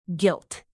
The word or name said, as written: gilt